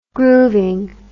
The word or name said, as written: grooving